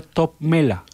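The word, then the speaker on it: Toppmöller: Que me dicen que se pronuncia como, se escribe Topmöller, pero se pronuncia Topmöller.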